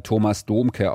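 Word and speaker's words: Thomas Dohmke: Please welcome Thomas Domke. – Wir würden ihn eher Thomas Domke aussprechen.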